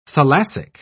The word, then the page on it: thalassic: British English